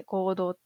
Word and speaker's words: 黄道: で、そもそもですね、二十四節気七十二候というところなんですけども、これは太陽が移動する天球上の道っていうのを黄道っていう、黄色の道って書いて黄道って読むんですけど、それを 24 分割したものが二十四節気と呼ばれてます。